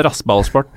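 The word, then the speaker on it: RasenBallsport: Ja, rassballsport, eh, de skal vi komme tilbake til, dessverre, men Er det riktig uttalt, Kallesen? Det er riktig uttalt.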